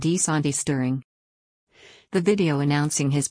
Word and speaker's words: DeSantis: pronunciation, DeSantis insisted his last name should be pronounced as winner.